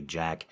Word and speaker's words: Jack: You can feel free to call me Jack, because it rhymes with welcome back.